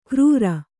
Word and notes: ಕ್ರೂರ: krūra

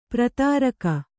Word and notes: ಪಾರದರಿಗೆ: pāradarige

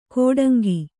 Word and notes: ಕೋಡಂಗಿ: kōḍaŋgi

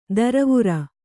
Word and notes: ದರವುರ: Kannada

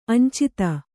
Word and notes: ಅಂಚಿತ: añcita

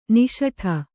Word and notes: ನಿಸುಟು: nisuṭu

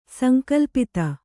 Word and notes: ಸಂಕಲ್ಪಿತ: sankalpita; Kannada

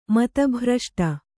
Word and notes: ಮತಿಭ್ರಷ್ಟೆ: mati bhraṣṭe

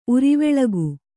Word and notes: ಉರಿವೆಳಗು: Kannada

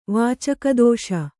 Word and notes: ವಿಚಿಕಿತ್ಸೆ: Kannada; vicikitse

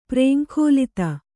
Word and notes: ಪ್ರೇಂಖೋಲಿತ: prēŋkhōlita

